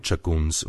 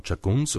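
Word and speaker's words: Tschagguns: Hinzu kam hier die rätoromanische Aussprache des Anlauts als Tsch und die im rätoromanischen gebräuchliche Betonung auf der zweiten Silbe. Das Gemeindegebiet von Chaguns umfasst knapp 57,7 Quadratkilometer.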